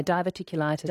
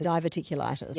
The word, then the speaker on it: diverticulitis: Di in Mandurah says, what about diverticulitis? Is that, I haven't pronounced that correctly. – Yeah, diverticulitis. – Diverticulitis.